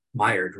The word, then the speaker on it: Maillard: But you take Ray Rogers, I pronounce it wrong, Maillard, is that how it's supposed to be? – Maillard reaction, yes.